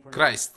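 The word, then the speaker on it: Christ: На английском языке оно произносится в укороченном виде «Христ», «Крайст». Итак, мы узнаем, что по сути мы произносим это слово неправильно.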